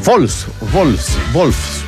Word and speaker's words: Wolfsburg: No, ese Wolfsburg, porque la W se pronuncia como V corta, ¿no? O V, ¿no? Se dice V. Y la V, que es la B, la V se pronuncia F, por eso se dice von der, von en el son cosas de la...